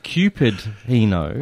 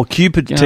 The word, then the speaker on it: Cupertino: Now, you didn't say that properly. It's Cupertino. – Oh, Cupertino. Oh, yes, it is. – No. – It is Cupertino.